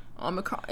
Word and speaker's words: Omicron: Omicron.